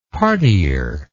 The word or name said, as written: partier